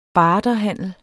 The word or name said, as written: barterhandel